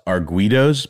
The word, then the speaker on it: arguidos: I think the Portuguese word for that is arguidos, Ben. Is that how you pronounce that? – Well, I don't speak Portuguese, but you're correct, Noel.